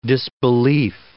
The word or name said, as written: disbelieve